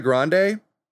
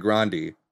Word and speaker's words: Grande: Ariana Grande, not Ariana Grande. It's pronounced Ariana Grande. That's how her family pronounces the name.